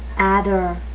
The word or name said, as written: adder